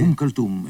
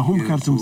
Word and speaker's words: Umm Kulthum: Esse músico que eu não vou usar a pronunciar, que tu disseste anteriormente, é Umm Kulthum, é uma cantora egípcia. – Ah, Umm Kulthum, sim.